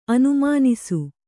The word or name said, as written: ಅನುಮನಸು